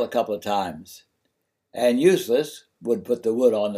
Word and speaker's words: Ulysses: Useless's Old Dog was aired on Kentucky Educational Television in a program of a videotape interview with Ulysses, pronounced Useless, Vanover, of McCreary County, Kentucky, and aired on January 5, 1977.